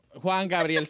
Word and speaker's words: Juan Gabriel: Juan Graviel. – Juan Gabriel, señor.